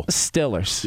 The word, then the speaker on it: Steelers: You got to replace the E's with an I. So it's still. – Steelers. – There you go. – The Pittsburgh Steelers.